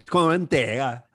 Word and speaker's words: Conte: And and he corrected my pronunciation of Conte. I went to school with Contes, Chris Conte, Dave Conte, but it's Conte. – Conte. – How do you say it again? – Just Conte. The only thing Conte. You have to change.